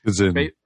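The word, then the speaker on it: Kzin: Kazin. – Kazin.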